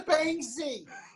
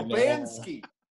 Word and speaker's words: Banksy: Like, any scrawl of anything on the street, oh, look, it's a Banksy! Or Bansky. Or a lot of people call him Bansky. – People can't pronounce it too.